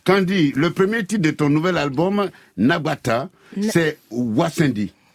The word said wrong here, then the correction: Nataba: Ouais, Nataba. Tonton. – J'ai prononcé ça de façon française, non? – Hein? Donc c'est Nataba qui veut dire ensemble.